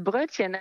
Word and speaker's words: Brötchen: Tell me about Brötchen. Is that how you say it? Brötchen? – Yes, I'm quite impressed. Brötchen, exactly.